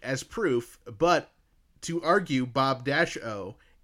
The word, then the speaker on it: Bobo: And it's spelled B-O-B-O with a capital O. So to me, that tells me that that's now the third thing I've seen that says Bob-O. – Yeah. – You seem to think it's Bob-O.